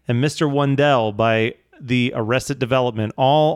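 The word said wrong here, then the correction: Mr. Wendal: Love your pronunciation of Mr. Wendell, by the way. – Mr. Wendell.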